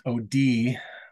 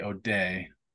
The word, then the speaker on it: O'Dea: And in Ireland, I guess it's pronounced O'Dea, but the OD. – Americans say O'Day. O apostrophe DEA.